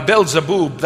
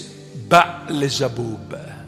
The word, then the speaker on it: Beelzebub: Now, Beelzebub, that's an English pronunciation. It's Baalzebub.